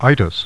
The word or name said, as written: Itys